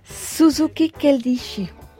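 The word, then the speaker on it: Suzuki Keiichi (鈴木慶一): Suzuki Keiichi, je prononce bien ? – Exactement.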